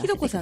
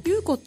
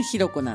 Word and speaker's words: 裕子: ひろこさんってね、ゆうこって書くんだけど、ね、読み方がひろこなんです、ね、そうなんです。